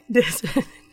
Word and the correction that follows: diaspora: How do you pronounce it? – Diaspora. – A diaspora. Black diaspora.